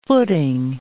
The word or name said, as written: footing